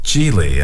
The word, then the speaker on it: Gigli: And, for example, this year I'm sure that Ben Affleck and Jennifer Lopez's movie called Geely will win that prize. – 哦，说和奥斯卡每年的大奖相对应的呢是奥斯卡，不是奥斯卡了，就是有些组织呢每年都要颁发最差电影奖。今年呢就有一个啊、，The name of the film again? – It's called Geely, and it's spelled G-I-G-L-I. – G-I-G-L-I.